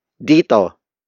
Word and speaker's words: dito: The first pronoun on this episode is dito. Dito. Dito. Dito is spelled as D-I-T-O.